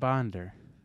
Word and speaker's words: Onder: Bob Onder. Bob. – O-N-D-E-R.